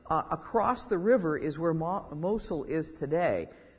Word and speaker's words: Mosul: When we had so much early information on the war in Iraq, the city of Mosul, I think that's how you pronounce it, M-O-S-U-L, Mosul, was in the news every night.